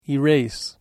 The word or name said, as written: erase